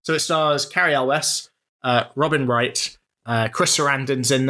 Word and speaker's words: Elwes: So The Princess Bride is an actor that I have, since our discussions on him a few weeks ago, I have Googled the pronunciation of his surname. That is Cary Elwes. So really? Is that how it's actually said? – Elwes, yeah, it's Elwes. I've been pronouncing it Yules for the vast majority of my life.